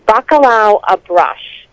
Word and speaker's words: bacalhau à brás: It's bacalhau à brás is how you pronounce the dish, mm-hmm.